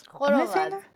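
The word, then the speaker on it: khorovats: It's called a kororats? Kororats? – Kororats. – Okay, thank you.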